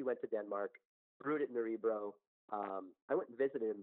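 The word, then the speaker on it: Nørrebro: It's Nerebro's in the neighborhood of Nerebro, which is in Danish. It's like Nerebro. It's like the strange. You know, O with a slash through it, which is Scandinavian pronunciation.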